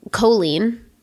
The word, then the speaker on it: Colleen: C-O-L-L-E-E-N is very Irish, right? – Right. – Some people say Colleen. They overpronounce the O. Okay. And some people just fly right past it and go clean. Hey, Colleen. Like a hard K into the lean.